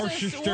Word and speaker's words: Worcester: Yeah, it's W-O-R-C-E-S-T-R, and it's Worcester. – How do you get Worcester out of that? – Because we don't pronounce R's, Frost.